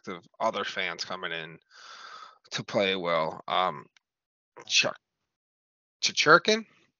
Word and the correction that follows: Chychrun: does that name sound right? Did I say that right? You're laughing. No, how you say it? – What's the first name? – Jacob. – Chychrun. – Yes.